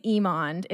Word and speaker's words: Emond: Okay, first of all, your last name is Edmond, correct? – No, so glad you asked. My last name is Emond.